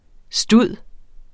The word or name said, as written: stud